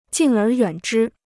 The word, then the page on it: 敬而远之: jìng ér yuǎn zhī